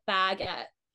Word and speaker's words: baguette: They're like, you need to put the emphasis on the bag, like baguette. I say baguette, like forget, only baguette.